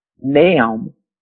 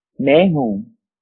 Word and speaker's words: Nahum: The fifth one is Nahum. Nahum. Nahum 1 and 9. It's spelled N-A-H-U-M.